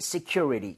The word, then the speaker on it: security: 많은 분들이 security라고 읽으시더라고요. 그게 아니라, Security. – Security. So, C에 가깝게 발음하는 거를 더 추천을 드리고, C 아니라 C에 가깝게. 그리고 그것보다 사실 U의 강세를 많이 주셔야 됩니다.